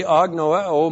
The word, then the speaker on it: agnoeo: And then the verb agnoeo. A-G-N-O-E, long O. Agnoeo.